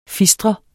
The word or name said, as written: fistre